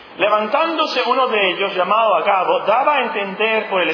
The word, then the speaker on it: Agabo: ¿Así se pronuncia Agabo? ¿No tiene acento? No puede ser Agabo, ¿o sí? Agabo, muy bien, Agabo.